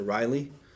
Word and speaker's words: Ryle: Ryle, right? Or Riley. Some people pronounce it Riley. R-Y-L-E.